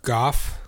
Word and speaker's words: Gough: How do you pronounce G-O-U-G-H? – G-O-U-G-H. – The man who plays Alfred, his name is Michael Gough? Gough? – Goff. – Goff? – Could be Goff.